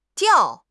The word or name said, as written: diao4